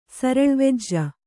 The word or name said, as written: ಸರಳ್ವೆಜ್ಜ